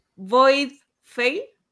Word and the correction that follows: Voidfall: Pero estuviste, muy bien, Voidfall.